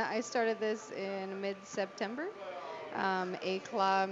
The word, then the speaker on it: éclat: Éclat, yeah. You have to sound kind of pretentious when you say it. – Yeah, éclat culinary. – The T is silent, éclat.